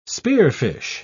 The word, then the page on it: spearfish: British English